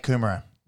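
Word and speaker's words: Kumerow: how do you say his last name? Kumaro? – Yeah, Jake Kumaro.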